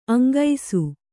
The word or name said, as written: ಅಂಕಿಸು